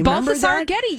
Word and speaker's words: Balthazar Getty: And then she got involved in another one with Balthazar Getty, or how would you say his name? – Balthazar Getty, yeah.